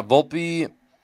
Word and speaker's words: Volpe: Volpe.